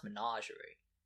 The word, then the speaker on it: Menagerie: I've listened to a bunch of people talk about that specific event in Destiny, and it's pronounced Menagerie.